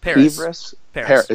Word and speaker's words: PVRIS: It's PVRIS. – Paris. – Is that Paris? Okay.